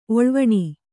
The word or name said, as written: ಒಳ್ವಣಿ